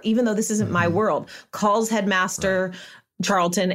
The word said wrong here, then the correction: Charleston: and says, did I say that right? Headmaster Charleston. – I think you forgot the S.